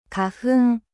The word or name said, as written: 花粉